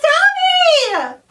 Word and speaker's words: Dobby: It is Dobby!